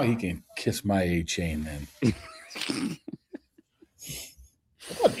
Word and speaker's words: Achane: It's Achane. It's not Achane.